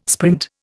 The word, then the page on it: sprint: Indian